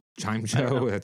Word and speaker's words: Chimecho: is it Chimicho? I often mispronounce Pokemon names, but there's... – Chimecho, that's probably right.